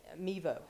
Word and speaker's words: Mevo: It's called a Mevio, I believe is the way that you pronounce it. It is Mevo, actually. M-E-V-O.